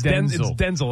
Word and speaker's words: Denzel: And Denzel, didn't he say that everyone's been saying it wrong? It's like, it's Denzel? – It's Denzel. His mom calls him Denzel.